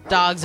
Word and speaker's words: Dogue: It's called, well, it's spelled D-O-G-U-E. It's pronounced like dog, but think of like if a French snooty person said dog.